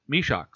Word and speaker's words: Mysak: I did that with Jan as well, and he pronounces it Mysak. So that's what I'm going with, Jan Mysak.